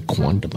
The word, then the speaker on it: quondam: Quondam. It is a Latin word. That once was. And this is how it's pronounced. Probably quondam, I would guess. – Quondam. – Yeah, quondam.